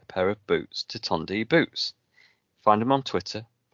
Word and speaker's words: Tondu: Tondi Boots, but how would you find Tondi Boots on Twitter, Mike? Because it's not how you say it. – Tondi is T-O-N-D-U.